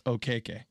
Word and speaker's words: Okeke: Okeke.